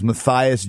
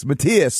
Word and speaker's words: Matthias: I grew up in Canada, in Winnipeg, Canada, and of course, not having any idea how to pronounce German names, my whole life I always thought your name was Matthias Jabs. And then the first time I went to Germany, everyone told me, no, it's Matthias.